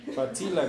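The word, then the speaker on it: facila: Fatsila, gracias a Angélica. Estamos 3-2. ¿Cómo se pronuncia? Fatsila. Fatsila.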